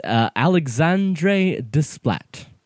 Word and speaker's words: Alexandre Desplat: It's Alexandre Desplat.